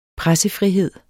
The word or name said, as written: pressefrihed